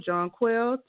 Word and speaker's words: Jonquel: Let's welcome Jonquel to the show.